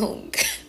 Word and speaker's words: Jung: So his name is pronounced Carl Jung.